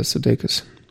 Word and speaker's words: Sudeikis: Äh, Sudeikis.